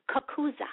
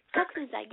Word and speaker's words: Coccuza: My last name is pronounced Cocuzzo. Mm-hmm. – Cocuzzo. Okay. – Cocuzzo.